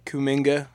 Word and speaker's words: Kuminga: Personally, I mean, I would have Jonathan Kuminga.